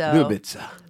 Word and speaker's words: Ljubica: Ljubica. – Nobody can pronounce it. So. – Ljubica. – Yeah. Because I you heard the L. I didn't hear the L. – L J U B I C A.